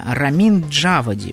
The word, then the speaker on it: Рамин Джавади: Сейчас, секундочку, как же правильно ударение. Рамин Джавади. Вот так правильно будет произнести.